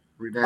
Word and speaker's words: Redan: when those band tapes started first coming out, it was a battle of the band, and it was like how you pronounce it, like Redan? – Redan.